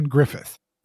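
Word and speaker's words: Gruffudd: No, it's spelled Ioan Grufford, and it's spelled Ioan Grufford, but it's pronounced Ioan Griffith because Welsh is weird.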